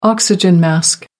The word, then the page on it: oxygen mask: American English